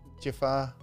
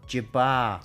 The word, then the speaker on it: Jabbar: Jafar. – Jabbar.